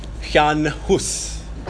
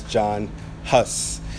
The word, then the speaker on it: Jan Hus: So today, we come to Jan Hus, properly pronounced, but we would know him as John Huss.